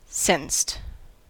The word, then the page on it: sensed: American English